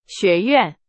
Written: 学院

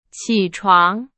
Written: 起床

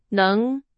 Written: 能

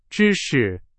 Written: 知识

